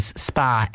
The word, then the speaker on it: Spah: And thank you to the listener who spent the wonderful weekend in the Celtic Ross Hotel in Roscarbery to say that the receptionist's surname is pronounced as Spa. It's spelled S-P-A-H.